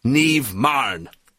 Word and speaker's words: Niamh Marron: I'm joined this morning by Niamh Marron.